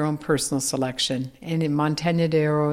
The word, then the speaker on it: Montaña de Oro: Montaña de Oro. I've got to pronounce it more correctly. After I've noticed you were all using the Inya properly. Yes. So Montaña de Oro is so extensive, and it has so much variety, and it has mountains, of course.